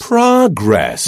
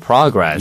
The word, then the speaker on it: progress: P-R-O-G-R-E-S-S. Progress. – You know, my British friends say progress. – 맞습니다. – American people usually say progress. – 맞습니다. 그래서 영국 발음에서는 progress 이렇게 발음하고요. 이중모음으로. 미국식 영어에서는 progress처럼.